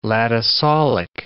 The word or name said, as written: latosolic